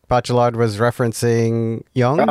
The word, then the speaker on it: Bachelard: And it started with, I might say this name wrong, Bachelard. – Bachelor, Gosh, you're right.